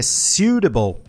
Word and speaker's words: suitable: Em inglês, que é suitable, suitable. Pessoa lê escrito S-U-I-T-A-B-L-E. É muito comum ouvir erros como suitable ou suitable. Não, a gente inverte o que está escrito com o I para I-U. Suitable.